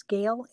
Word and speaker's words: Gail: It's Gail A. Kabrick, and it's my last name is spelled K-A-B-R-I-C-H, and Gail is a short G-A-I-L.